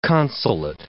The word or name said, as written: consulate